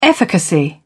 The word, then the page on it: efficacy: British English